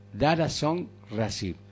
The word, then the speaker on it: Daraxonrasib: Recuerden el nombre: Daraxonrasib. Daraxonrasib. Se escribe D-A-R-A, la X, O-N-R-A-Sib.